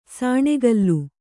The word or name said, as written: ಸಾಣೆಕಲ್ಲು